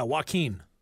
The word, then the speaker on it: Joaquin: Joaquin Phoenix, is that how you say it? – Yeah, Joaquin. – Joaquin Phoenix, all right.